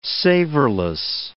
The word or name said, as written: savorless